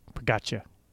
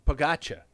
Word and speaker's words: Pogačar: He says it's Pogačar. – Pogačar. – Pogačar. That's how you say it.